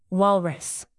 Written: walrus

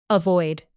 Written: avoid

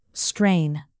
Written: strain

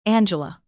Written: angela

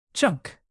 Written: chunk

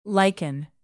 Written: lichen

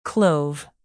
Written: clove

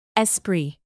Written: esprit

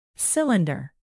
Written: cylinder

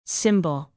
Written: symbol

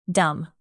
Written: dumb

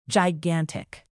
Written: gigantic

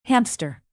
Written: hamster

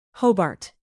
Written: Hobart

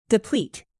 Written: deplete

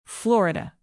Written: florida

